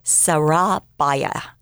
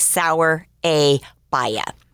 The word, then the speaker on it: Surabaya: So Dr. Medhurst suggested she worked in a special place called, and this is where I don't know how to pronounce it, but it looks like Surabaya. Surabaya. To me, it looks like sour. Baya.